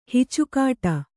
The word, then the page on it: ಹುಚ್ಚುಗಟ್ಟು: huccugaṭṭu